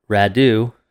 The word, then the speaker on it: Radu: Radu, spelled like ragu, but with a D,